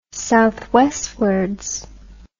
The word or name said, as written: southwestwards